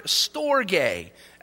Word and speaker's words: storge: The second one is the word, the Greek word, storge. S-T-O-R-G-E is the best way to spell it in the English language.